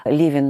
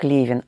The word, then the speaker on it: Левин: И поэтому, вероятно, возникло предположение, что из-за того, что какими-то чертами своей судьбы, своей жизни, своего характера Левин напоминал автора Анны Карениной, то, вероятно, им казалось логичным произносить имя этого героя не как Левин, а как Левин.